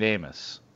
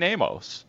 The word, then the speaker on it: Amos: It is Adrian Amos. It is not Adrian Amos.